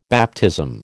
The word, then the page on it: baptism: American English